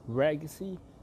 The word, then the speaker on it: Ragacy: It's actually pronounced as Ragacy. It's just the same as legacy, just that I'm using my front three letters, my first three letters for the name of this brand that I have.